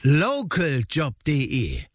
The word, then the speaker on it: Localjob: Ja, aber die Betonung mehr auf local. – Localjob.de.